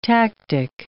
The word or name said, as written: tactic